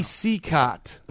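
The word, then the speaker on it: Cicotte: I got to go to a ruling to Dan Long here because you might have the actual person, but that is not how his name is pronounced. – How is it pronounced? – Yeah, I can't help you with the pronunciation. – It's Eddie Seacott.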